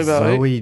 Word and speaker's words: Zoey: Zoe Jones. So she got the surname and her first name rhymes with Bowie.